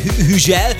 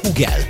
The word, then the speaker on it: Hugel: Hugel, bocsánat. – Nem. – Hüzsel. – Hüzsel. – Francia nyelvtelten Hüzsel, amúgy pedig nagyon durva, de Hugel a helyes kiejtés.